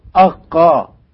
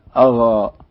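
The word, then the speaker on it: آقا: الفاء تنطق كما في العربية، والقاف سبق أن أشرنا إلى أنها يحدث فيها تبادل مواقع، يعني على سبيل المثال يكتبون الألف الممدودة وقاف وألف، ألف ممدودة قاف ألف، كان من المفروض أن تنطق أقا أقا، لكنهم عند النطق يقولون أغا أغا، يعني القاف نطقت قريبة من حرف الغين.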